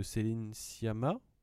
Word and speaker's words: Céline Sciamma: Portrait de la jeune fille en feu de Céline Sciamma, je le prononce bien.